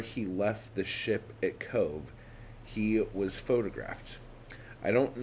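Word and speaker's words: Cobh: Cove, pronounced Cove, approached.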